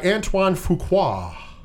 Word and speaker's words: Antoine Fuqua: It was written by David Ayer and directed by Antoine Fuqua.